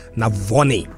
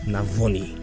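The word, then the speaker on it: Navone: Mariano Navoni. – Navoni, there's phonetic pronunciation here. Navoni. – Do they say Navoni? – Yeah. Mariano, Argentina. – Mariano Navoni. – Yeah, Mariano Navoni. Not Navoni, Navoni.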